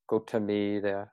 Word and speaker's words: Gotami: Gotama Buddha, sometimes we refer to this Buddha because of the family name, and Gotami, the long E sound, just means the female equivalent of Gotama.